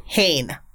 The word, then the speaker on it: Hoene: A one Dick Vaughn, apparently is pronounced Hane. Sure. It's spelled H-O-E-N-E. Hane.